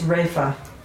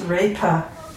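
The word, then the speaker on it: refa (रेफ): The pronunciation for it is refa. And it's kind of, it must be Hindi or something, because Sanskrit it's repa. But when we're talking about ra, they call it refa.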